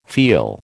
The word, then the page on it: feel: American English